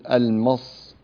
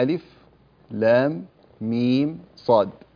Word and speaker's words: المص: الأحرف النورانية لها أحكام خاصة وهي تتلى بشكل معين، فكل حرف ينطق باسمه ولا تنطق الكلمة يعني هكذا تقول المص، لا، وإنما ألف لام ميم صاد، أربع كلمات.